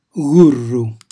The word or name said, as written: gurru